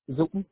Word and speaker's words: Dung: Yeah, my legal name is kind of hard for people to What is your legal name? – Zoom. – Zoom? – So it's hard for you to pronounce it right. Zoom? Yeah. – It's okay. Okay. How do you spell it? – Yeah, my D-U-N-G. – Dung. – Like baby. – Okay. – D-U-N-G. – D-U-N-G. – D-U-N-G. – Yeah, yeah, G like George. Okay.